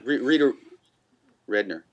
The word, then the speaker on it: Rudner: Rita Rudner.